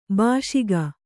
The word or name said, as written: ಬೇಸಿಗೆ